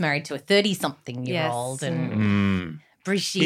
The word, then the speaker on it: Brigitte: Brigitte.